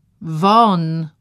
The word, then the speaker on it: Vauxhall: Won, powtórzyła raz jeszcze. Myśli po tym rozkazie pierzchły na wszystkie strony. Jakiś tylko szum w uszach, szelest wyrazów. Wymawia się won, a pisze się Vauxhall.